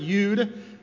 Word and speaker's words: Yod: And let's talk about Yod. Everybody say Yod. Some people pronounce it Yud, but I have a habit of saying Yod.